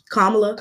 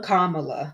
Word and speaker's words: Kamala: But I did hear, and her name is pronounced Kamala. – Kamala. – Yeah. – Let's be respectful.